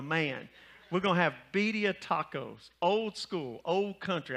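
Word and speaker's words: birria: And it's going to be bedia. You like that, Jerry? You like how I did that to you? You're going to have bedia tacos. Did I say it right? Yeah. Okay, I said it good, right?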